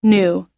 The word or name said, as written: new